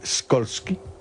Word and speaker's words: Skolsky: No, señor, no es SH, es SKOLSKY. – Impronunciable. – A la señora... – Raskolnikov. – ¿Cómo se pronuncia? Porque es polaco. ¿Cómo se pronunciaría en polaco Skolsky? – Skolsky. Como tú lo has dicho.